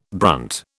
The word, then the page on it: brunt: British English